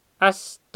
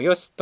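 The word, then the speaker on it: 葦: よしずがけ、よしは、あしともよしとも発音する。もともとはあしと発音されていたようだが、あしの発音が悪につながるので、よしと発音するようになったもので、どちらも同じイネ科の多年草である。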